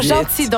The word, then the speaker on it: Jeanty: Ah, oh, Gentil, donc on dit ça. – Ouais, s'écrit J-E-A-N-T-Y. – Oui, exactement. Et je la connaissais pas avant parce qu'en fait, elle était pas ici.